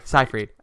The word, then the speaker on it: Seyfried: Amanda Seyfried. – Like a Z. Zed Seyfried. – Amanda Seyfried. I think I say it with an S, but like with a very soft S.